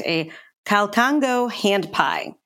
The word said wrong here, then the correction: Calcaño: You got pretty close. Calcaño.